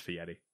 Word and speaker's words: Fieri: There's no T in the name, but it's not Fieri. – Yeah. It's like it's Fieri. I know it's clearly pronounced differently than it's spelled.